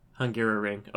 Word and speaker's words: Hungaroring: Hungaroring.